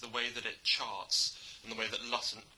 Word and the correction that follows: Hutton: We've now Lutton. Attempted three or four different pronunciations of his very straightforward name. – The way that Hutton sits very much between two epochs, and which kind of centre around the Second World War.